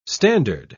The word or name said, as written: standard